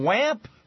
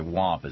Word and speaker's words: Wamp: Congressman Wamp I think it's actually WAMP is how he pronounces it, or he would prefer that it's pronounced WAMP, the Republican, Zach WAMP, the Republican from Tennessee, going on MSNBC today to talk about health care.